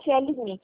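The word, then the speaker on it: FLSmidth: É FL Smith? É FL Smith, é isso? – Isso mesmo, FL Smith.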